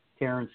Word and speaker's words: Terrance: Terrence is spelled T-E-R-R-A-N-C-E.